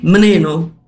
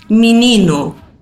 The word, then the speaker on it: menino: Si ustedes escuchan algún portugués de Portugal decir la palabra menino, por ejemplo, ¿no? ¿Saben? – Sí, Chico, ¿no? – sí. – Ellos van a decir menino, ¿no? En cambio, nosotros ahí en Brasil vamos a decir menino, ¿no? Pronunciamos todas las vocales.